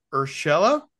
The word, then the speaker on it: Urshela: The Angels acquire Gio Urshela.